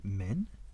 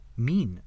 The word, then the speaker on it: mien: M-I-E-N. How the fuck do you pronounce that? M-I-E-N. Boop. What's that? Is it men? Mean, okay.